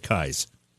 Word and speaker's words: Keyes: Just so nobody's fooled like I was, Your last name is pronounced Keyes, but it's spelled K-E-Y-E-S. Some people would think that would be Keys, but you pronounce it Keyes. – It is pronounced Keyes. I went through college as Keyes just because it was easier, but everybody goes right to Keyes when you see my name. – Right. – Yeah, it's K-E-Y-E-S.